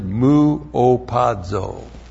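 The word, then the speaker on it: Mu opazo (μυωπάζω): And this is the Greek word muopazo. That's M-U long O, P-A-Z long O. Mu opazo.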